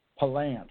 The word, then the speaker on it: Palance: And he always corrected everybody. It was Palance is where he would say. – Oh, really? – His name. – Okay. – It was mispronounced.